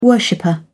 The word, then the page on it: worshipper: British English